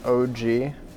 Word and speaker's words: Og: His first name is OG. So Og.